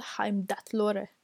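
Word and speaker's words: Heimdallur: تلفظ درست اسمش در گویش ایسلندی برای مثال هایمداتلوره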